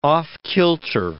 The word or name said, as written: off-kilter